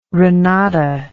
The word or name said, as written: renata